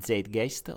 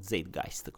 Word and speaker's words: Zeitgeist: E, ehm, ne viene, mm, se ne parla in uh, Zeitgeist o Zeitgeist come si vuole pronunciare.